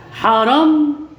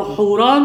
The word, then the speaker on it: حاران: حاران أو حوران ممكن تتنطق كده أو كده إنها HU أساسا، فاليو ممكن تتنطق A أو واو.